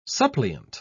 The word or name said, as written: suppliant